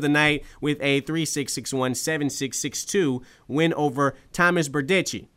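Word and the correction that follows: Berdych: Am I saying that right? – Burdick.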